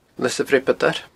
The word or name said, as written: Lios a' Bhreabadair